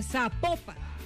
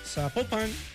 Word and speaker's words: Zapopan: Oiga, nos escriben, nos escriben los oyentes y que no se, no se pronuncia Zapopan, sino que se pronuncia Zapopan. – Zapopan.